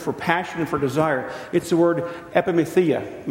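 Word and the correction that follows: Epithumia (ἐπιθυμία): Make sure I'm saying that right. Epithumia.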